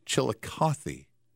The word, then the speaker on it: Chillicothe: They apparently the final E on there is a long E, but there's no indication of that if you're not from there. There's no, it's not double E to make it a Chillicothe. It just looks like Chillicothe.